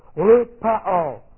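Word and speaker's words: lupeo (λυπέω): L-U-P-E, long O, lupeo.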